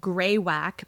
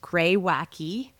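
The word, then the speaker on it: Greywacke: So we both have, I thought the name was Grey Whack, but it's actually pronounced Grey Wacky, which is on their website, Grewacke, but you pronounce the E.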